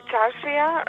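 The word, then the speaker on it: čaršija: Allora, ho sbagliato, credo, immediatamente la pronuncia. Charsia? È questo il nome del bar? – Sì, si potrebbe dire Charsia, di solito in serbo-croato, appunto nelle Charsie serbe e bosniache, ma anche Charsi in turco, Charsia in albanese, ma anche semplicemente bazar.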